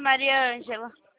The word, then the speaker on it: Mariângela: dia, eu falo com a Marijângela. – Não é Marisângela, é Mariângela. – Me perdoa pela pronúncia, Mariângela.